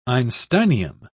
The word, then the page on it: einsteinium: British English